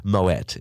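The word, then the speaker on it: Moët: And so they're trying to like show the 18-year-old Insta whore that like, look, we have Moe. And it's like, well, I wouldn't brag about that, but we have Moe. So I look at me speaking like I'm from Bali. Moet is actually how it's pronounced.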